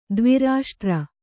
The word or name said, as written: ದ್ವಿರಾಷ್ಟ್ರ